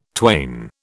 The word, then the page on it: twain: British English